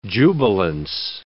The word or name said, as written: jubilance